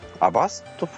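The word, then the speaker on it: Avast: iPhone 内のオリジナル写真をクラウドアップしつつ、写真アプリの写真を圧縮し、ストレージの空きを作るアプリ、アバスト、アバストフォトスペースでいいのかな、読み方を試すというアプリをの紹介をしてくれてます。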